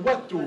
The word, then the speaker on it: Watteau: Alors dans le Nord, on dit Antoine Watteau. Hein. Vous savez qu'il est de Valenciennes. Alors normalement, il vaut mieux le prononcer à la nordique, Antoine Watteau.